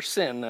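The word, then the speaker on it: sin (σύν): It's soon koineo. So it's the same word as above with soon added in, or sin, it would be pronounced. If you synchronize your watch, chronos is time, sin is with.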